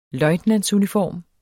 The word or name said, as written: løjtnantsuniform